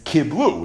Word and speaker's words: kiblu: If you look at the word kiblu, it's written chaser. Kuf beis lamed, but it's pronounced kri is kiblu, which is lashon rabbi. Yeshem lemikri, yeshem lemesleiris. We just discussed the sugi at length. So it's written kibel hayehudim, but it's pronounced kiblu hayehudim.